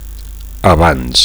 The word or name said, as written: abans